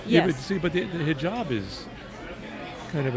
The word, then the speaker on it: hijab: It's hijab. Is that what you said? – Hijab. – Hijab. You were right. I said it wrong. – Is the accent on the... No, the hyphen's on the job.